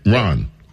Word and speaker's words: Ran: It's spelled, you would think it's Ran, but it's R-A-N, Ron.